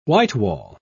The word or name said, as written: whitewall